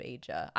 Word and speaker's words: Aja: And onto the episode itself, starting at the beginning, we get that cold opening with the backstory on the Redstone of Aja. I say Aja because that's how they pronounce it in the Japanese um, dub, the Japanese voiceover.